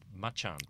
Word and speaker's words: Macan: Just a thought, as a family man, I'm more interested in electric Macan. And we know that's pronounced Machan because we've been corrected on from time to time.